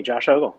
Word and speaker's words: Ogle: Hey, it's me, Josh Ogle.